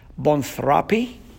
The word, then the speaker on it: boanthropy: And I'm going to spell it B-O-A-N-T-H-R-O-P-Y. Bonthropy. – B-O-A-N-T-H-R-O-P-Y.